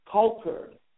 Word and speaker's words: Colcord: Now, Colterd, that's spelled C-O-L-C-O-R-D. Now, they inform me out here that the O sounds like an A. So it's not Colterd, but it is Colterd, Oklahoma.